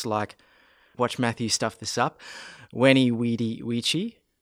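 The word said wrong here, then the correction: vici: No, that's the hard C, remember? Weeny, wiki. Weedy, weeky.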